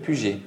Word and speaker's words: Puget: Est-ce que tu peux nous dire ton nom de famille pour pas que je ne l'écorche Alors, c'est Yannick Puget. – Puget comme euh, lui de livre, du coup.